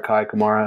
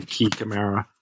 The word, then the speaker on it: Kai Kamara: When have you ever heard his name pronounced Key? – I know. – You never heard it before? – No, I've never heard anyone say Key Camara.